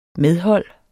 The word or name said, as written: medhold